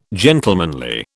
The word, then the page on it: gentlemanly: British English